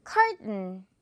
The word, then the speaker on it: curtain: T 옆에 약한 모음 있어요. 지금 이 부분 강하게 소리 안 나거든요. 그리고 N이라서 curtain 이렇게 또 발음을 해주는 거죠. curtain보다 curtain. 오케이?